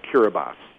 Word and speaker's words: Kiribati: It's not spelled like it sounds. – No, it's spelled like Kiribati, but it's pronounced Kiribati. Yeah.